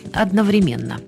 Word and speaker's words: одновременно: А теперь обратимся к вопросу о грамотной постановке ударения в слове «одновременно». В словаре «даля» ударение стоит на третьем слоге «одновременно».